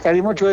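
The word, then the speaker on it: Kalimotxo: Cali, se escribe Calimo, Cali con K. – Sí. – Y la CH con TX. – ¿Qué es? – Es Calimocho, es gaseosa con vino tinto.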